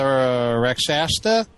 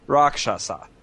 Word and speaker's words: Rakshasa: Is this the one that we're talking about, the Rakshasta? – Yes. – Or Rakshasta? – Don't even. I sent you an email with a pronunciation and everything. Come on. Rakshasa. It's Rakshasa.